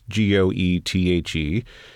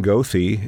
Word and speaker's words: Goethe: If they if they didn't know how to pronounce G O E T H E, you mm-hmm. know, you, have got people going Gothi and it's Goethe, you know, and uh, wow.